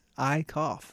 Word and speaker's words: Eickoff: And that's how you say it. Eickoff.